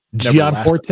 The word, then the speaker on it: Gianforte: Montana Governor Greg Gianforte. Is that how you say his name? – It's how you say his name.